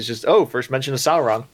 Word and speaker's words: Sauron: So when I was growing up, I saw it, whenever I saw it spelled out, I would read it like dinosaur, so Sauron. – Yeah. – And I've heard it pronounced both ways, but I think Sauron's the correct one.